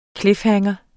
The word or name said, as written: cliffhanger